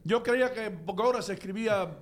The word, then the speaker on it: Bogota: Se escribe igual. – Yo vivo cerca de Bogota. – Pero se pronuncia diferente, Bogota. Aquí, cuando yo llegué aquí, brother, yo no sabía... Yo decía, ¿dónde quedará eso, Bogota?